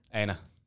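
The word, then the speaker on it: Éanna: Iana Harrison. – Aina. – Aina Harrison. – Also, that's Aina is Irish for Enda.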